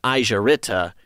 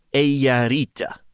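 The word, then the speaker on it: Eija-Riitta: She says, "Finnish words are pronounced just as they are written. Here's a recording of how it's said correctly. You can repeat after me. – Eija Riitta. Eija Riitta." – So we'd been saying Aisha Ritta, and we should have been saying Eija Riitta.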